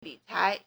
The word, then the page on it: 理财: lǐcái